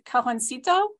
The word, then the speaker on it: Cajoncito: Her bilingual debut collection, and please correct my pronunciation if I butcher this, Cajoncito.